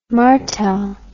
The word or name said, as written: martell